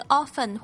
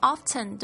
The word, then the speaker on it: often: 好，这边我们稍微注意一下这个发音， often 念这个 often 或 often 都对，但是前面呢这个是标准读音，就是 often O-F-T-E-N，就像我们汉语里面的这个字就是熟悉的熟，有些人会读成熟，但有些人会读成熟，但不管怎么样念呢大家都可以明白这是什么意思，只是个人习惯而已。